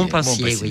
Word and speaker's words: Montpensier: Oui, Montpensier.